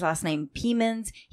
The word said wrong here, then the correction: Plemons: He's the one married to Kirsten Dunst. – Oh, Jesse Plemons, yeah.